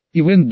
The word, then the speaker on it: Ивен: Ивен Долохов, представил его Робертс, ставя ударение на первый слог в имени.